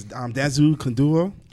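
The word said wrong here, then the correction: Danza Kuduro: Danza Kunduro.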